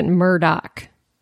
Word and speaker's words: Murdaugh: Well, the most annoying thing is that it's spelled like Murdaugh, but they pronounce it Murdoch.